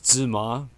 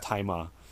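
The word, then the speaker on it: Tzi Ma: His name is spelled T-Z-I Ma. I always thought it was Zi Ma, but it's actually pronounced Tai Ma.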